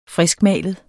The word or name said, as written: friskmalet